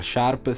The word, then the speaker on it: Sharpe: Edward Sharpe con... – Sharp. Occhio alla pronuncia.